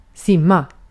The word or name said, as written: yüzmek